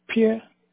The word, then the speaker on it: Pierre: Pierre. – Could you spell that P-I-A? – P-I-E-R-R-E. – Oh, P-I-E-R-R-E.